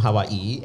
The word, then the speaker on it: Hawaii: And it's not from Hawaii. And it's... – How come you know how to... That's the correct way to say Hawaii.